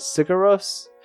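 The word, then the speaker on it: Sigur Rós: I think it's Sugar Rose. Is that how you say it? Sugar Rose? – Oh, yeah. Yep.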